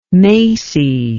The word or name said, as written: Macy